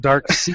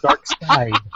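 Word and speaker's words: Darkseid: It's so Dark Side, not Dark Sea. It's Dark Side. Side.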